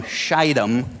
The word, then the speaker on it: Shittim: It's a long I and not a short one. It just changes things a little bit to the ears. It's from Shittim.